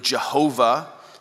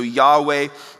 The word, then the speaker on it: Yahweh: so Yahweh would be the right way for us to pronounce it as best we're able to.